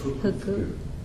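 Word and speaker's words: Fuku (福): Now, how do you say this? Is this a Japanese character only? – Yes. Yeah. – How do you say this? – Fuku. – Oh, okay. So in Chinese, it's fu.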